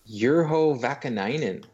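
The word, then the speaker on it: Urho Vaakanainen: And segue, Mark, speaking of hard names to pronounce, Juho Vakanainen. – You did it.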